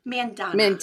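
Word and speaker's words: Mandanna: How do you say that last name? – I think it's Sangu Mandana. – Mandana. It's long vowel.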